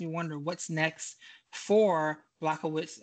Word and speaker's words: Błachowicz: Błachowicz, Jan Błachowicz is how you say his name.